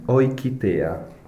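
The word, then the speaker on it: Eukitea: E Oikitea. – che vuol dire Oikitea? – Allora, Oikitea vuol dire Europäische, cioè Kinder- und Jugendtheater. Tradotto in italiano si scrive EU, ma si pronuncia OI.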